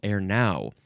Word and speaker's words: erenow: The first word in this episode is ernow. You gotta emphasize the second syllable, ernow. E-R-E-N-O-W.